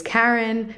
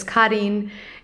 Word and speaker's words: Karin: I even texted Jeanne a few days ago being like, girl, I was calling my credit card company and usually when they ask for my name, I say my name is Karen because it's easier. And this was the first time I said, hi, my name is Karin.